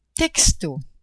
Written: texto

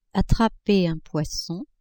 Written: attraper un poisson

